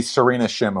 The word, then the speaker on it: Serena Shim: It's like at the nonprofit group, Shabini Award. Am I saying that right? – The Serena Shim Award. – Shim Award. Thank you.